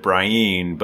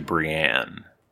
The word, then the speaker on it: Brienne: Yeah, that he pronounces it Brian, but the show producers made him change it to Brienne.